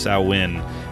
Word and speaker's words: Samhain: 2 was also the first one to introduce the idea of, as they say repeatedly, Samhain, correctly pronounced Samhain,